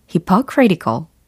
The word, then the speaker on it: hypocritical: 좀 길긴 하지만 요거 발음은 어떻게 될까요? hypocritical, hypocritical, hypocritical 이라고 하시면 되겠습니다. hypocritical, hypocritical. hypocritical. 뭔가 하마와 critical이 붙은 느낌이랄까요? hypocritical, hypocritical, hypocritical. 이 리듬을 잘 기억하시고 Isn't that too hypocritical?